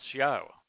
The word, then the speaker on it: Yahuwah: You know, the Torah teaches us how to pronounce Yahuwah. Not only does it have the ah sound that is repeated twice in Yahuwah,